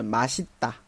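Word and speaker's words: 맛있다: 它的原型的话是 맛 있 다 啊， 맛 있 다，注意发音，注意收音啊，不要和我们那个喝麻西大混淆起来啊。这里是 맛 西大啊，收音有的啊，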